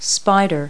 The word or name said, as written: spider